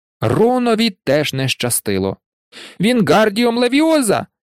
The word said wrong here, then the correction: Вінгардіум левіоза: «Ти не так кажеш!» – дратувалася Герміона. «Має бути Вінгардіум левіоза! Леві треба вимовляти протяжно й легко!» «Кажи сама, коли така розумна!» – огризнувся Рон.